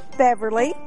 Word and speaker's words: Beverly: Beverly. – Beverly, how do you spell that? – B-E-V-E-R-L-Y.